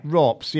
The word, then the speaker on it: RoPS: How do you say that? Rops? – Yeah. – Rops, yeah. They're a Finnish side.